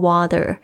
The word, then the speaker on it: water: 那我非常喜欢 water 这一个英文字哦，因为它跟这个英式的发音呢是有非常大的区别，在我在教学英语的时候也常会拿这个 water 来举例，就是在英式发音的时候呢，这个 T 的声音会听起来比较脆，它会发 T 的声音， water、那甚至 ER 呢在这个英式发音也是比较不卷舌的。